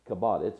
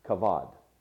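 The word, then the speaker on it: kavod (כבוד): It's called kabod. It's actually pronounced kavod.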